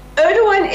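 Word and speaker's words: Erdoğan: Erdogan, is that how you pronounce it? – Erdogan is correct.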